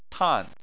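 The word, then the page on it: tan: tone 4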